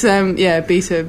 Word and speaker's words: B3TA: Well, we have to pronounce it Beta, don't we? – We do. It's like Leet Hacksaw speak. It's like, you know, that's how fucking down with the kids we are. It's, Beta, B3TA.